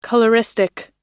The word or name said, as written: coloristic